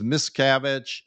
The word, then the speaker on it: Miscavige: How do you pronounce his name? And none of the Scientology lawyers said, none of them responded. They didn't even want to say his name out loud. Wow. I mean, seriously. So one of the plaintiff's lawyers says, Miscavige.